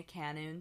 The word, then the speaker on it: Kenoun: And follow me at Sabrina Canoon. So my last name is spelled K-E-N-O-U-N.